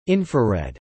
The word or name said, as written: infrared